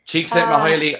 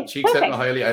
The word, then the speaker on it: Csikszentmihalyi: Csikszentmihalyi, I know how to pronounce it. I practiced it. I wrote it down. – Okay, Csikszentmihalyi, I learned that off from a PhD.